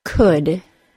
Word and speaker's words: could: There are three words, could, should, and would, that are pronounced with the other U sound that are spelled O-U-L. In these words, there is no L sound, and the vowel sound is the other U sound. In other English words spelled O-U-L, it should be assumed that the vowel sound is not the other U sound, and that the L sound is also necessary.